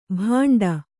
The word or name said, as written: ಭಾಂಡ